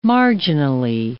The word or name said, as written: marginally